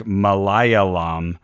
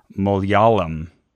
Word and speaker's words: Malayalam: And the language that I love the name of, because it was a palindrome, to me it looked like Malayalam, but apparently that's pronounced Malayalam.